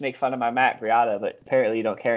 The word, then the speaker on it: Breida: Or Matt Breida. Rhymes with Chiata in the 14th.